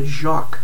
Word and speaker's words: Joque: One that I know for sure is the spare parts harnesses, the most recent version of the, okay, let me say it right, the Jacque, J-O-Q-U-E, has a little pocket in the back for a bullet vibe, so those are kind of cool.